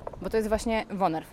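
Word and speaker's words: woonerf: Mówimy wonerw, wunerw, z urzędników różnie przypadają określenia. – Było sprawdzone. Słowo jest konkretne, pochodzi z języka niderlandzkiego i prawidłowo je się wymawia jako wonerw.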